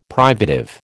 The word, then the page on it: privative: American English